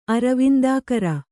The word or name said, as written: ಅರವಿಂದಾಕರ